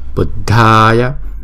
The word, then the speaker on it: Buddhaya: Nhớ là chữ đờ, hờ là chúng ta đọc là đờ. Buddhaya. Chữ A là hai nhịp Buddhaya. Chứ không phải là Buddha, không phải là thờ ha, không phải là Buddha mà là Buddhaya.